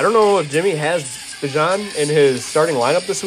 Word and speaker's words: Bijan: Bijan is the pronunciation. Bijan just sounds cooler.